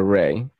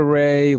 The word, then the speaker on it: Rai: If you YouTube Mad Raver Ray, two R's, R-A-I. My name is spelled R-A-I, by the way. For you watching, still don't know how to spell my name. It's spelled R-A-I. Mad Raver Ray, you will find videos of me from 2006.